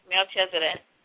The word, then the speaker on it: Melchizedek: Well, I thought about that too at one point, like you know, in terms of Melchizedek, but Melchizedek. I don't know if I'm saying it right, but that's who he is. – Yeah, that's right, Melchizedek.